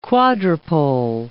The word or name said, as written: quadrupole